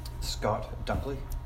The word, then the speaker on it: Scott Dunkley: Scott Dunkley. – Scott Dunkley. Can you spell that for me? – S-C-O-T-T-D-U-N-K-L-E-Y.